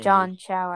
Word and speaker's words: Chau: John Chau, I think is his how you pronounce his name. Yes.